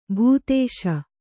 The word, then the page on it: ಭೂತೇಶ: kannada